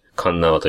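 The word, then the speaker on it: 鉄輪: こちらは鉄の輪っかと書いて、神縄と読みます。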